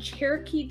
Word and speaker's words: Cherokee: He has Choctaw and Cherokee descent.